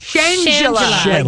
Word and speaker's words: Shangela: Shangela. – Like Angela with a sh at the front. – Shangela. – Shangela. – Shangela. Not Shangela. – Shangela. – I like Shangela.